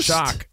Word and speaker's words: Choq: It's called Shock, and it's spelled C-H-O-Q. – Yeah, buddy. – It's all about that male vitality stack. – Mall. C-H-O-Q.